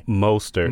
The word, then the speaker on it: Mostert: Is it Mostert or Mostert? I heard it both ways today. – Mostert. – Mostert with a long O.